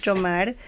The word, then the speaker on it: Jhomar: Mi nombre es Jomar, se escribe J-H-O-M-A-R, entonces nadie lo escribe así.